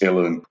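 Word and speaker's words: Uilleann: It's pronounced Illan, which apparently is Gaelic for elbow, because we have bellows on them.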